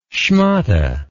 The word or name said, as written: schmatte